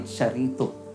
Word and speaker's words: charito: Or tawag po na iba ay charito. So, depende lamang po sa inyong pagkakabigkas. It's just but okay. Pero syempre, ang uh, mga Greek kasi ganun daw po yung pagkakabigas, karito. So you are highly favored.